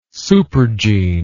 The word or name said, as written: supergene